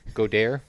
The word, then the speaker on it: Goedert: Is that how you say his name? Goedert? – Yes.